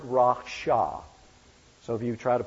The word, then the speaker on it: rasha: And the word is rasha, R-A-S-H-A, rasha.